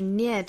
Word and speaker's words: near: 首先我们做英音的语音讲解，那我们看第一个单词就是 near 这个词。 N-E-A-R 那这里想讲一下这个词它第一个辅音也就是这个前鼻音的发音，很多同学会把这个音跟汉语的那个呢的那个音混淆了。